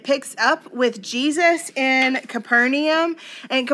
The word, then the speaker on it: Capernaum: The official way to pronounce this town is Capernaum.